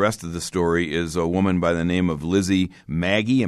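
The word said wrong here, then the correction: Magie: Am I pronouncing that right? – Yes, Lizzie McGee.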